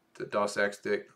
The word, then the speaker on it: doxastic: And he kind of touches on this a little bit, you know, with the doxastic. It's spelled with an X, but it's pronounced with an S.